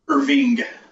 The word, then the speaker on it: Irving: No, here in Texas, it's Irving. It's with a G at the end instead of an E.